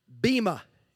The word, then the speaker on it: bema: Because the Greek word translated as judgment seat is the word bema. You ever heard that term before? And it's spelled B-E-M-A.